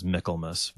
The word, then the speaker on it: Michaelmas: Michaelmas. – But yes. – Michaelmas. – Yeah. Hmm. Yeah. That was the pronunciation I found online, was Michaelmas. But yeah, that's just like Christmas, right?